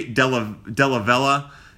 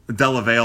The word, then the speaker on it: Dellavalle: His last name is D-E-L-L-A. V-A-L-L-E. But the E and the A are swapped when you pronounce his name. So it's like Perry Delavella. Delavella, I believe. I'm going to call him Delavella. Delavale all the time, but it's Delavella.